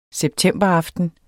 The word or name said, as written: septemberaften